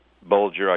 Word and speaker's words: Bulger: Bulger, I guess I'm pronouncing his name right.